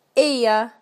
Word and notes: dolls corner: British English

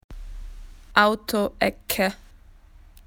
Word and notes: car corner: Australian English

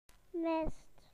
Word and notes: fog: British English

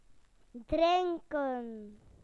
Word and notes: to drink: British English

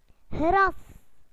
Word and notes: grass: British English

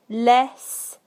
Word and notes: less: British English

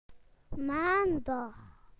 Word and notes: Monday: British English

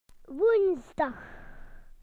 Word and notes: Wednesday: British English

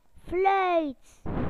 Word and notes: whistle: British English

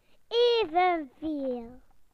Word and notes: equal: British English

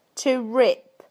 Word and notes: to rip: British English

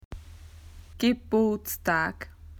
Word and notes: birthday: Australian English

